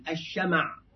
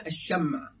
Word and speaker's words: الشمع: يقول: فقد ذهب بعض أهل اللغة إلى أنه بهذا الضبط مولد، أما بفتح الشين والميم ففصيح. يعني نقول الشمع، مع أن الناس ينطقون الشمع بتسكين الميم، فبعض أهل اللغة قالوا: لا، لما تسكن الميم هذا مولد، هذا لم يكن عند العرب، العرب لم تنطق بهذا.